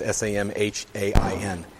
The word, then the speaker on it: Samhain: It's Samhain.